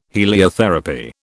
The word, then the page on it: heliotherapy: British English